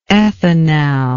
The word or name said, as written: ethanal